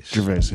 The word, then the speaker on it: Gervais: Gervais, yeah.